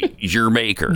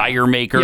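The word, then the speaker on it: D'yer Mak'er: And it is pronounced, by the way, Jermaker. – Jermaker, right? – Okay. A lot of people pronounce that every which way. – Yeah. – Dire Maker. – Yeah, I used to say Dire Maker. – Dire Maker. It's Jermaker.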